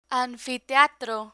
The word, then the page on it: anfiteatro: spanish